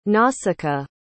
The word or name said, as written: nausicaa